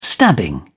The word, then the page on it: stabbing: British English